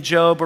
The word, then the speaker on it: Job: Job's in the Old Testament. A lot of people think it's a job because it's spelled the same way, right? But it's Job.